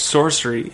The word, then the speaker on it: Sorsari: It's spelled S-O-R-S-A-R-I. Ooh. But it's Sorcery, and he's a producer out in Edmonton.